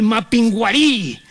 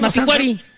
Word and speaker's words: Mapinguari: No, es que no tiene acento en la I. – No tiene acento en la I. – Mapinguari.